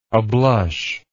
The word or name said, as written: ablush